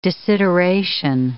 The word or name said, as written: desideration